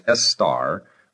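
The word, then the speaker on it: Istar: The name and cult of the goddess were derived from Babylonia, where Estar, that's I-S-T-A-R, pronounced Estar, represented the evening and morning stars and was accordingly androgynous.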